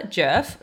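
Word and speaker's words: Djerf: So yeah, Matilda Jerf, the D is silent.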